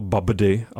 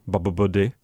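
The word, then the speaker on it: Babbdi: Jmenuje se to, netuším, jak se to vyslovujeme, píše se to Babdy a to ty prostřední B jsou dvě, Babdy.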